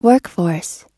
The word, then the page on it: workforce: American English